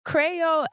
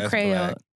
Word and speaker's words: Kreyol: Okay, so Crayole Essence. I've mentioned Crayole. – That's black. – And I love it because it's spelled K-R-E-Y-O-L, okay? – That's new black.